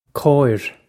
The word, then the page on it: cóir: Irish